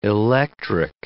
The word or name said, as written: electric